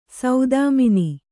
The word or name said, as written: ಸೌದಾಮನಿ